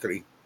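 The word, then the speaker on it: Grès: It is Madame Gris, just Gris. And you got to give a little R, Gris.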